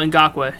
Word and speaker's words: Ngakoue: Oh, Ngakoue.